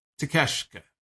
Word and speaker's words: Takeshita: Or Takeshita, I'm sorry. Jesus Christ. I was trying to say the last name earlier as I was writing his name down in my notes. For whatever reason, I couldn't say the last name. I just couldn't get it. Couldn't do it. It was Taz, I think, on commentary also was botching the pronunciation of the name. It was Takeshita, though.